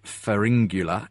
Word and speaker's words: Pharyngula: because you run the science blog Pharyngula. I don't know if I'm pronouncing that correctly, though. – You are pronouncing it correctly.